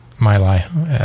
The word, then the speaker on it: Mili: Is that what the way you pronounce it? It's M-I-L-I. – Mili? – Or Mili, one or the other. – I'm going to say Mili, like my life.